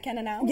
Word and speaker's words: Cannonau: Did you have the Cannonau? – Yes, that is what I had.